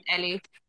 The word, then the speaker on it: Elli: He's named after SVU, but we just call him Ellie. Like Elliot spelled E-L-L-I, but it's pronounced like E-L-L-I-E.